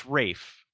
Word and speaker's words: Ralph: No, it's Rafe. – Name is spelled R-A-L-P-H. Ralph. I don't care how he wants to pronounce it. R-A-L-P-H is Ralph.